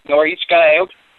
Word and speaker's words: Norichika Aoki: Norichika Aoki? – Yeah, that was actually pretty good at it. Norichika Aoki.